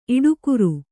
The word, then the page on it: ಈಡುಕಾರ: kannada